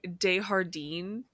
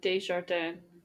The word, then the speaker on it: Desjardins: I correct me if I'm wrong, but in French, wouldn't they pronounce the J? – Yeah, it should be Desjardins. – Yeah. – Oh, they're doing like a Spanish pronunciation.